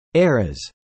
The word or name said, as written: eras